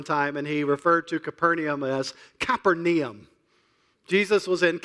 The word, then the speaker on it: Capernaum: At least we believe that's how it's pronounced, Capernaum. The New Testament language is now a dead language, meaning people do not still speak it. But we believe it was pronounced Capernaum.